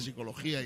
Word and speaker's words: psicología: Yo he preguntado mucho, y la P, dice, la P no se pronuncia. ¿No? ¿Y para qué la ponen? Entonces, no se pronuncia. Estas cosas que queremos hablar precisamente de psicología